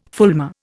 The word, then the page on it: fulmar: Indian